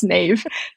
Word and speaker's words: Niamh: Yeah, it's my initials because my name is spelled N-I-A-M-H and that would just be terrible for business because it's pronounced Neve.